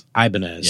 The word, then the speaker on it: Ibanez: Ibanez. Did I say that correctly? – Ibanez. – Yeah. There you go.